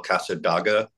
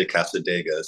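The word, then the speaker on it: Cassadaga: By the time Casadega came out, which I always want to call Casadaga because that's kind of how it's spelled. But on the record, on one of the samples, they say Casadega. So, and that really, it sounds better.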